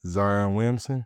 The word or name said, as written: Zion Williamson